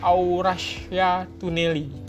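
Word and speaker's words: Avrasya Tüneli: Ada namanya Afrasyatunale Au, bacanya Aurasia Tuneli.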